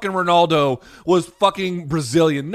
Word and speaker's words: Ronaldo: his name is 100% pronounced Ronaldo.